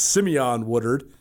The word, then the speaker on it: Simeon Woodard: He asked me specifically to make sure we pronounced his name right this year. It's Simeon Woodard as the corner on the other side there for the Cats, and then their backups.